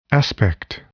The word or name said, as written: aspect